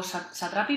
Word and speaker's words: Satrapi: Pues Persepolis es, un cómic de una chica iraní que no sé si lo voy a pronunciar bien, pero se escribe Marjan eh, Strapi, o Satrapi, perdón,